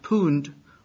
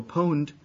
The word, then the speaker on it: pwned: I'm told by some high school kids in the United States that this means, this is pronounced pooned or pwned, and it's a, obviously a typographical error, from the word owned.